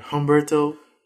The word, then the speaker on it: Humberto: Yeah, Humberto. – I'm sorry. – No, it ain't. You gotta roll your R's.